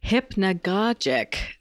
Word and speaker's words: hypnagogic: So, we're going to be discussing what I have come to understand is pronounced as hypnagogic states.